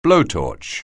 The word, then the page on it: blowtorch: anglais